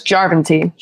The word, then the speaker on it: Järventie: No, it's Järventie. Sure. This is on the first syllable in Finnish.